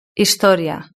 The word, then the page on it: historia: Spain Spanish